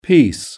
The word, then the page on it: piece: British English